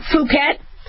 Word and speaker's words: Phuket: One of the strains in the vaccine, Phuket. I got to remember not to do a short U.